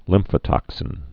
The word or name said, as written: lymphotoxins